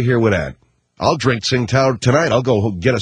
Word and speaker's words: Tsingtao: Then there's Tsingtao. Tsingtao, some people pronounce it.